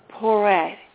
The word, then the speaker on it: Porete: Marguerite Poiret, P-O-R-E-T-E, Poiret.